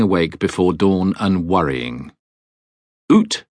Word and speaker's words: Uht: Ut, UHT, pronounced Oot,